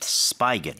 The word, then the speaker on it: spigot: 근데 어디서 하냐면, 어디에 있냐면 at a public spigot이라고 얘기를 하는데 is that a spigot or a spigot? – It's actually spigot. – Spigot. – It's spelled S-P-I-G-O-T.